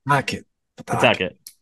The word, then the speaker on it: Pawtucket: How do you pronounce it, man? – Pawtucket? – Yeah. – Pawtucket. Pawtucket. Yeah.